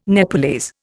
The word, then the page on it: Nepalese: Indian